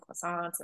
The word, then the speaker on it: croissant: Um, and then of course just your normal French fare of like baguettes and croissants and oh, I just said it correctly, croissant.